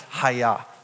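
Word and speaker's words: haya (היה): But as you can see behind me, God's name is derived from the Hebrew verb to be, and it's pronounced haya. Let's do a little karate in church. Everyone say haya. Haya, haya. Yeah, basically the same thing as what you say in karate.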